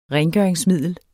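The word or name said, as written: RENGØRINGSMIDLER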